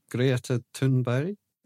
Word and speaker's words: Greta Thunberg: Come on, pronounce it properly. You're Swedish. Pronounce it properly. – Greta Thunberg. – That's better. – I think that was pretty good. It sounded more Swedish than us.